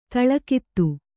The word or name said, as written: ತಲೆಗುತ್ತ